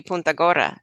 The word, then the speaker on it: Punta Gorda: I say Punta Gorda, but I believe in Spanish it would be Punta Gorda. So, and that I just butchered that. So, but yeah, it should be the long U.